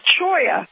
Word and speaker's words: cholla: Like, for instance, one plant that he uses is the choya, and it's spelled C-H-O-L-L-A. It's a Mexican plant, and so it's pronounced in Spanish.